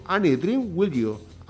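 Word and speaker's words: Any Dream Will Do: Jason Donovan amb Any Dream Will You Do. Repeteixo, Any Dream Will You. Així sí, eh, ja està pronunciat correctament.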